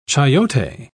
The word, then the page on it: chayote: British English